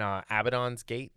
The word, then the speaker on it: Abaddon's Gate: And it becomes very important in Abaddon's Gate.